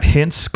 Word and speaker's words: Hintz: They got Hintz coming up. Is that how you pronounce his name? – Yes. – Okay, good. I actually got that one right.